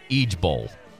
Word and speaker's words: IJBOL: And apparently, they don't say IJBOL. They pronounce it Ege Bowl.